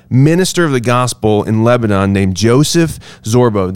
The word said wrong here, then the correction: Zorob: Did I say that last name right? Zorbo, is that how you say it? – Zorob. – Joseph Zorob.